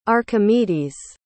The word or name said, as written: archimedes